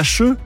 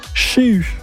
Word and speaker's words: Cheux: Et donc, on ne prononce pas Cheux, mais on prononce Chéu.